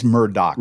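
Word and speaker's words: Murdaugh: There's a lot of people mispronouncing it, but it is Murdoch.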